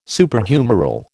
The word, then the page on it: Superhumeral: American English